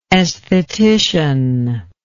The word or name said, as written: esthetician